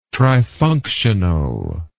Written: trifunctional

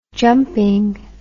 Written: jumping

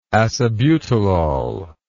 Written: acebutolol

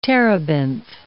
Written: terebinth

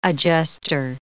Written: adjustor

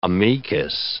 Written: amicus

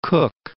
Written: Cook, Mount

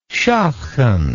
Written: schatchen

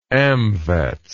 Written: AMVETS

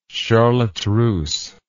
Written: charlotte russe

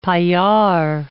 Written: paillard

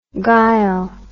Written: gyle

